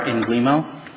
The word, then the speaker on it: Inglemo: My name is Michael R. Inglemo. That's how I've been pronouncing it since law school. It seems like it's been easier for the clients and as well as the law school teachers.